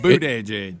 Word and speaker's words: Buttigieg: Don't say booty. Boot edge edge.